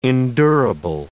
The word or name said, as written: endurable